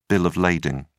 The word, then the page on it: bill of lading: British English